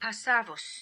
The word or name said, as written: Passavus